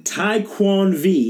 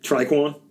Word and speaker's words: Taekwon V: Now, say it with me. Taekwon V, and it's spelled like Raekwon, the chef, but it's just a T.